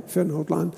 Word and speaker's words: Vernhoutlaan: Ik herinnerde mij in de voorbereiding dat zo lang geleden, toen woonden we nog in Wilnis, Vernhoutlaan. Zeg ik dat goed? Ja, hè? Vernhoutlaan.